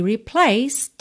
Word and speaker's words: replaced: Então, cannot be replaced. Replaced. Temos uma letra E aí, C-E-D, no final de replaced, mas a gente não pronuncia.